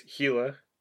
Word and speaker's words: Gila: So, well, and then Gila woodpecker, obviously, is Gila, like Gila monster.